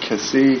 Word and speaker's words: Kesee: We have this case, I'll pronounce it Kessee, K-E-S-E-E.